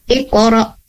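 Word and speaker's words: Iqra (اقرأ): Contoh di surah Al-Alaq ini, Iqra, Iqra bismi, nah, Iqra bisa terputus suaranya karena di situ ada hamzah yang sukun. Maka putuskan bacaan ketika ada hamzah yang sukun. Iqra bis iqra wa rabbukal akram.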